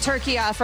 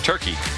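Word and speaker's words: Türkiye: I will be saying it turkiya from now on. – And my entire lifetime, have been, and we have been, incorrectly pronouncing turkey. – Because we've been saying turkiya. – It like dumb Americans, which, you know, basically how it goes.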